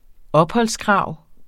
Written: opholdskrav

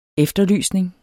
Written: efterlysning

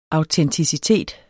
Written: autenticitet

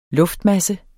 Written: luftmasse

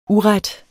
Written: uret

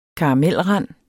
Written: karamelrand

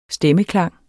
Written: stemmeklang

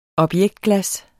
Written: objektglas

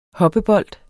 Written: hoppebold

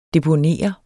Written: deponere

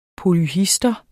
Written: polyhistor

